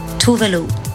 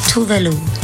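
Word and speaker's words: Tove Lo: Wie heißt sie denn jetzt? Toflo? Tovelo? Wir haben aber eh direkt nachgefragt. – It's Tovelo. Voulez-vous Tovelo?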